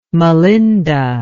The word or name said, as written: malinda